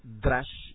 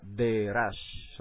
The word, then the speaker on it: Derash: La otra es Derash, Remes, muy bien, Derash, algunos dicen Drash y eliminan la E, pero esos son los askenazitas, los sefalditas ponen la E donde va, Derash, son dos sílabas, Derash, ok, y finalmente Sod, S-O-D, esos son los cuatro fases, digamos, o aspectos del sistema de interpretación bíblica judío tradicional.